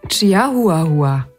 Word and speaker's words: Chihuahua: Chihuahua. – Der Dalmatiner. – Dameneinteiler. – Der Golden Retriever. – Klöten-Reitriver. – Ja, Schmunzler mit dem Chihuahua ist auf deiner Seite.